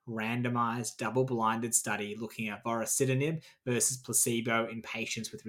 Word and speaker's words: vorasidenib: Why don't you take us away on our final ride, one last ride, with Indigo, the study of vorasidenib, that's how you pronounce that word, in gliomas.